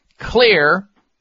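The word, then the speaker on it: Xlear: And that is called Clear Max Nasal Spray. And Clear is spelled with an X, X-L-E-A-R.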